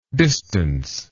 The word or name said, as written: distanceless